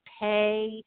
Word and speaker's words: Pei: On LinkedIn and Twitter, I'm Dr. Marissa Pay. By the way, it's not pie. It's pay like money.